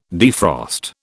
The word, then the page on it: defrost: British English